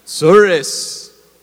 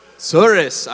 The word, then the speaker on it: Tsuris: No, you're not saying it right. Tsuris. Try again. Tsuris. I want to hear the weight of Jewish suffering when you say that, okay? Tsuris.